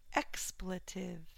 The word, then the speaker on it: expletive: Expletive, first syllable stress.